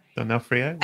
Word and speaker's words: D'Onofrio: D'Onofrio as Thor, and he just looked amazing.